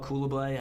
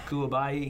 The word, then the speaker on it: Coulibaly: They got a rookie, Bilal Coulibaly. I definitely messed up his name. I'll make sure to pronounce it right. – Coulibaly.